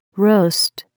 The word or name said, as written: roast